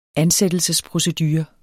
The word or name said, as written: ansættelsesprocedure